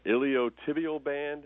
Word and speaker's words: iliotibial band: And the doctor diagnosed it as, I cannot pronounce this properly, but iliotibial band. Does that sound right? – Yes, sir. That's correct.